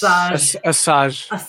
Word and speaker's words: Asajj: Aja Ventress. – Asajj Ventress.